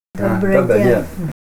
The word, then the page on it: Dobray d'en: Ninilchik Russian